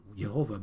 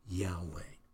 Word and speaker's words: Yahweh: In the English, it's the word Lord God, but in the original Hebrew, it is the word Lord, which is usually the word for Jehovah, or some pronounce it, I should say Jehovah, but the most accurate pronunciation is Yahweh.